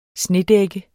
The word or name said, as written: snedækket